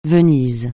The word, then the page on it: Venise: francais